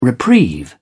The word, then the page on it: reprieve: British English